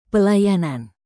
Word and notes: pelayanan: Indonesian

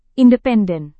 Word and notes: independen: indonesian

